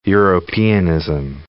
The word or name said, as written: Europeanism